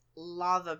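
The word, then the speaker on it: laver: And it's spelled L-A-V-A-R. – Yeah. – I think, but you guys pronounce it lava, don't you? Because I didn't realize there was an lava. R on the end.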